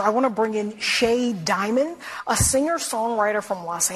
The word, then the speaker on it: Shea: Pronouncing S-H-E-A, Shay, is an act of violence in that version of America.